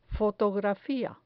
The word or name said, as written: fotografia